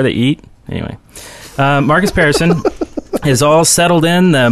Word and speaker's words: Pearson: And in the news today, Marcus Pearson, and that is how you say his name, by the way. It's Pearson, not person, not parson, not whatever.